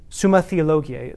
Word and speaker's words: Summa Theologiae: That's when he began writing a work called the Summa Theologiae, it depends if you pronounce it in Latin or in English, Theologiae, Summa Theologiae, the summary of theology.